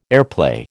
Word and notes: airplay: American English

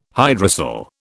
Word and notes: hydrocele: British English

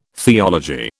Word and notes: theology: British English

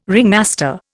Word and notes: ringmaster: Indian

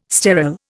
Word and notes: sterol: Indian

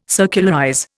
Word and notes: circularize: Indian

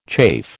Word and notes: chafe: American English

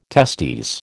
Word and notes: testes: American English

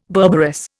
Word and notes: berberis: Indian

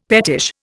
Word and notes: pettish: Indian

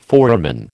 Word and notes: foramen: American English

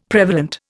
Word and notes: prevalent: Indian

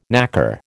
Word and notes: knacker: American English

